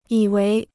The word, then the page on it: 以为: yǐ wéi